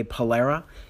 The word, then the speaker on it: Pullara: Pullara is my Instagram name, and you can find me on Facebook in my Facebook group, which is The Success Makers.